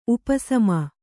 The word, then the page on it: ಉಪಸಮ: upa sama